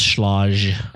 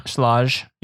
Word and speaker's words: Schlage: So, just first of all, I always thought it was Schlage. – Schlage? – Yeah. – You were sounding to be like all fancy with the pronunciation.